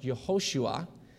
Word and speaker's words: Yehoshua: Jesus is from the Hebrew for Joshua, and Joshua will be pronounced Yehoshua.